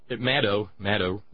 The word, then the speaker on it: Maddow: But then again, maybe the problem with Rachel Maddow, Maddow rhymes with shadow.